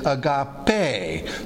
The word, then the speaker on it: agape: It just needs a longer tail, and so they think of the E as being... almost an H sound, eta, and so it is agape. Sometimes people don't realize what that thing is. So it's actually an E pronounced as an A, but it looks like an H, and that's how I remembered it first year Greek.